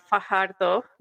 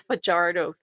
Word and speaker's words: Fajardo: Fajardo. I'm saying it right. That's how it should be. None of this Fajardo thing.